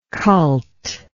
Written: cultish

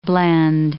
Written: blend

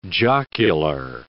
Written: jocular

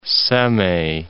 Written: Semey

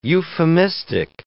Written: euphemistic

